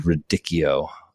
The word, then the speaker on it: radicchio: Like that's all great, but you can also do it out of like pistachios, radicchio, all kinds of, actually it's radicchio. I did the audiobook for Eat to Beat Depression and Anxiety.